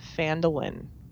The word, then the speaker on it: Phandalin: And In Volo's Wake takes place at first in the town of Phandalin, if I'm pronouncing that correctly. I had to say that a couple of times to make sure, because it sounds like mandolin.